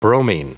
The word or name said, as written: bromine